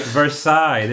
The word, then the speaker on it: Versailles: At least say it right. Versailles. There's Versailles. – No... – Versailles. Ver fucking sigh.